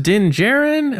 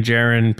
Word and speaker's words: Din Djarin: And he's like, is it pronounced Din Djarin? I'm like, yeah, Din Djarin.